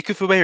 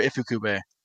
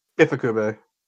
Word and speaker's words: Ifukube: How do you say, is it Ikufube or Ifukube? – Ifukube.